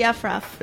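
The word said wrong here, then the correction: aufruf: The Afruf, that's what it's called. – Afruf, yeah. – Afruf.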